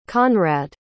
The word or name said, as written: conrad